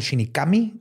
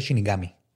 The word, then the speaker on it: Shinigami: Pero cuando lo conjugas, este Shinigami, o sea, es como Shinikami, le pones la G Shinigami.